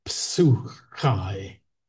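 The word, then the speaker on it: psuchai (ψυχή): He then sets it out in Greek, which I can't read, and then it says psyche, and then it says no, actually, phonetically, it should be pronounced psuchai.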